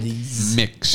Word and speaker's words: Mx: Mix is pronounced M-I-X.